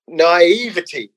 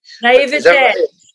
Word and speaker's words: naivety: I always say naivety, but it's actually naivety. There we go. Thank you. – Naivete. It's a French word.